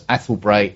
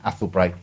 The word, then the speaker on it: Athelbrae: And their Twitter handle is Athelbray LTD. That is spelt A-T-H-E-L-B-R-A-E LTD. A-T-H-E-L-B-R-A-E LTD. Athelbray Limited.